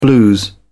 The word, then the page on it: blues: British English